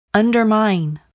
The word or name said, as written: undermine